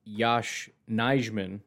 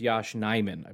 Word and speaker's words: Nijman: You know, it's kind of funny because I had somebody reach out to me and explain to me that it's not Yash Nijman, like I've been calling him. It's Yash Nijman, I believe is how you pronounce his name.